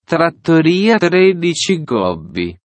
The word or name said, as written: Trattoria 13 Gobbi